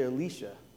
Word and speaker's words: Elisha: And as I was reading this, doing my preparation, I looked at the Hebrew on this a prophet's name, and I realized I've been saying it wrong all this time. And maybe you have been too. We've been saying Elisha. It's actually Elisha, looking at the Hebrew.